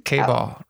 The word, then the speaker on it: KBall: KBall rhymes with ball.